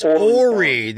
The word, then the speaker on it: Horry: It's Horry, the H is silent.